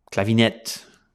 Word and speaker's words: Clavinet: Clavinet, in a French pronunciation there.